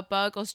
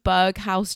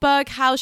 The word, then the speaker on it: Berghaus: Side note, I bought one of these jackets recently to try and be cool and fit in, but I was pronouncing it incorrectly. I literally messaged Mikey and my friend Susan saying, oh my God, guys, I've just got this new Berghaus jacket.